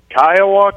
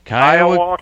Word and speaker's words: Kiowa: Kiowa, Kansas. – Kiowa, Kansas.